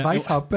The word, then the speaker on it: Weishaupt: But Weishaupt, you say it better than me. – Because I am German. Yeah, Weishaupt, but you're right on the money with how you pronounced it.